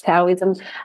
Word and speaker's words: Taoism: It's Taoism.